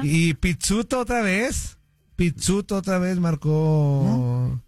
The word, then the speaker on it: Pizzuto: Él dijo que suena como pizza. Él dijo Pizzuto. Así. Mi nombre es. Mi, Pizzuto, entonces. – Se pronuncia como pizza.